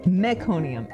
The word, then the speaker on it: meconium: It's meconium, M-E.